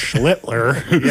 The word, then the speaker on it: Schlittler: That's got to say this right. – Get this one right. – Schlittler. – Yes.